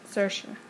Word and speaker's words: Saoirse: Saoirse, that's how you say her name, I think.